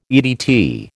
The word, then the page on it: EDT: American English